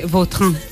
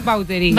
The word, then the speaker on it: Vautrin: Vautrin. Bueno. Pero aquí decimos Vauterín. – Claro, sí, sí. ¿No? – Pues, es que si me pregunta exactamente cómo se pronuncia, pues así. Claro, pero sí. Yo ya soy Vauterín. – Ya. – Está españolizado total.